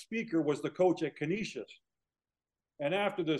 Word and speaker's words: Canisius: Canisius.